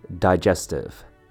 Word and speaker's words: digestive: Lastly, we have digestive. The first syllable here, die, has a long I sound, die. Jess has a short E sound, jess. Tiv has a short I sound, tiv, digestive.